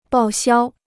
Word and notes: 报销: bào xiāo

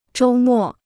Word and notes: 周末: zhōu mò

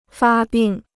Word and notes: 发病: fā bìng

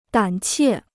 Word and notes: 胆怯: dǎn qiè

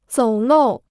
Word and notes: 走漏: zǒu lòu

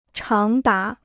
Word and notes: 长达: cháng dá

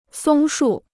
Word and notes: 松树: sōng shù

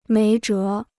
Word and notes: 没辙: méi zhé

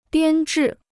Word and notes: 编制: biān zhì

